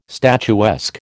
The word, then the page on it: statuesque: American English